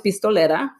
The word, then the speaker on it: Pistolera: So my band was Pistolera, which rhymes with Nopalera.